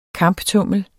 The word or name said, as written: kamptummel